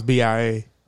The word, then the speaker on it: BIA: Bia. – Thought it was B.I.A. – No, I think they call it Bia. – Why they call it Bia? – Because that's how you pronounce it. B.I.A. Bia.